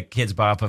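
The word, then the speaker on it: Kidz Bop: She mostly listens to Kid Bop's radio. – Kid Bop's. – Kid Bop's. Oh, that's such an uncle thing to say. It's Kids Bop, yeah.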